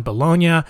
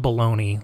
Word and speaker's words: Bologna: Because his uncle was Pope Calixtus III, Rodrigo lived a very luxurious life, studying law in Bologna. And I think it's Bologna. It's not Bologna. It's spelled Bologna, like if we're eating bologna, but I think it's Bologna, Italy. And he graduated as, quote, the most eminent and judicious jurisprudent, there it is, in his class, whatever that means.